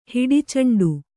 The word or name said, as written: ಹೊಡೆಚೆಂಡು